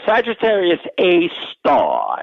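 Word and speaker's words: Sagittarius A*: No, it's called Sagittarius A star, and the star is usually typed as an asterisk, but that's the pronunciation.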